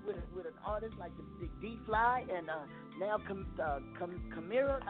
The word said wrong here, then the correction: Karima: I know I said it wrong. – Karima. – But, you know, Karima. – Kalima. – Mm-hmm. – All right. – Karima. K-A-R. Karima. – Karima, like Kareem. Okay, like Karima. Okay.